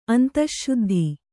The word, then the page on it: ಅಂತಶ್ಶುದ್ಧಿ: Kannada